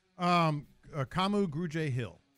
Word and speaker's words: Kamu Grugier-Hill: Kamu Grugier-Hill is how you pronounce it.